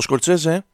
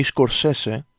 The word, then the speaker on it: Scorsese: Ο Σκορτσέζε ή Σκορσέσε, όπω ο ίδιο έχει πει ότι προφέρεται το όνομά του, δεν ήταν πάντα mainstream.